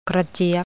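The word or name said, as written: กระเจี๊ยบ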